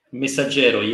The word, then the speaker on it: Messaggero: Il Messaggero, have I said that right? – Messaggero, yes.